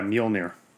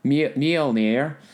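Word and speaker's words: Mjölnir: And do you know how to pronounce his, the name of it, the hammer? – Yeah, Mjolnir. – Mjolnir. which it sounds very like racistly French, you know, Mjolnir, but Mjolnir. – But it's Norse, and it's we're going to talk about that here pretty soon the next time that we get together.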